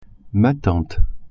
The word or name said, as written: tante